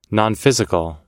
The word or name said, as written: nonphysical